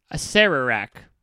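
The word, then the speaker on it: Acererak: Pronounced, hold on, I'm going to listen to it. Asarak. – Asarak, okay. – That's what this thing says. Okay, Asarak. I never noticed that extra R in there.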